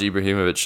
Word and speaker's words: Ibrahimovic: To my knowledge, Ibrahimovic sounds right.